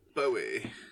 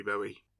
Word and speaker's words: Bowie: You know, people don't know how to pronounce his surname. Is it Bowie or Bowie, right? – I say Bowie. – Bowie. – It is Bowie because he was on John Peel when Duncan was born and he announced that his name was Zoe Bowie.